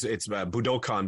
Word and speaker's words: Budokan: So we and Ryan's actually pronouncing it correctly. It's Budokan, but for all of us, you know, who the big album Live at Budokan. – Cheap Trick. – Everyone globally has been saying Budokan, but it's Budokan.